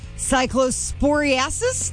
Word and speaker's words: cyclosporiasis: Health officials are warning people to take caution with fresh produce as an outbreak of cyclosporiasis. Did I say that right? – Good job.